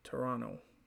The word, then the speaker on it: Toronto: For listeners who aren't from here, the second T is silent, so Toronto.